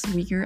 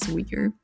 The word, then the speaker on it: Uyghur: I would also like to mention that in this podcast episode, I did mispronounce Uyghur as Uyghur multiple times, so I do want to apologize for that, but the right pronunciation is Uyghur.